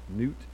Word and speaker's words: Nut: And this plague was against one of the gods, probably the god Nut, it's spelled Nut, but I think it's pronounced Nut.